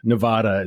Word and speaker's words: Nevada: So the third key battle in the nomination process for Democrats takes place this coming Saturday in Nevada, I think is how it's properly pronounced. Nevada is how it's really pronounced, where polls show that Bernie has a strongish lead of about 26%.